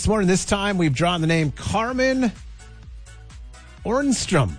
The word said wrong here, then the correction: Ormston: I think it's Ormston. – Ormston. – O-R-M-T-O-N.